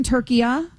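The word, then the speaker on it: Türkiye: So you're saying, honey, you're saying that in that language, it's always been Turkiya? – That's what he's saying, yes.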